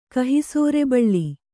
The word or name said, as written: ಕಹಿಸೋರೆಬಳ್ಳಿ